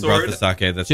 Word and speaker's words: sake: Sake.